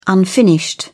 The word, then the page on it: unfinished: British English